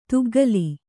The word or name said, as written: ತುಗ್ಗಿಲ್